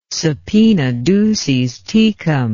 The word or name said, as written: subpoena duces tecum